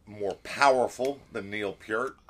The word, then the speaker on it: Peart: And it's pronounced Peart, not Peart. – That's the Canadian Peart. That's the Canadian pronunciation there. – Yes, that's his correct pronunciation.